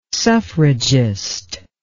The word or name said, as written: suffragist